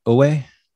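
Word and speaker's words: wu wei: Uwe? – Uwe.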